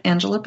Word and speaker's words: Angela: And I look forward to having you with us next time here on Lit Up with Angela Breidenbach. My name is spelled A-N-G-E-L-A. My last name is B-R-E-I-D-E-N-B-A-C-H.